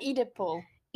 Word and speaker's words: Oedipal: So, according to psychoanalysis, the hysterical symptom is the expression of the impossibility of the fulfillment of sexual desire because of the fetus conflict. Am I saying it right? Oedipal. Oedipal conflict.